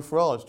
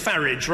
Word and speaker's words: Farage: Well, I'm glad he takes the English pronunciation of Farage rather than the rather palsy foreign-sounding one that he seems to prefer.